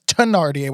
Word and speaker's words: Thénardier: I did do a bunch of Broadway shows, but I did Les Mis on tour, and it's Thénardier with a hard T, even though it is spelled with a TH.